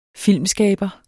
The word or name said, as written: filmskaber